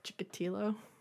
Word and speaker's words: Chikatilo: Chikatilo? – Yeah. I mean, it has an L in it. I realized that I was totally saying it wrong.